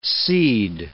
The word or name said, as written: seed